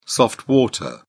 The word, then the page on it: soft water: British English